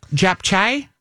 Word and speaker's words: japchae: Japchae.